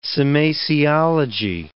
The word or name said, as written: semasiology